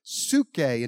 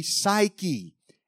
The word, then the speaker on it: psuche: Our soul comes from the Greek word psuche, and if you look at it actually in the English way of pronunciating that word, it would be psyche,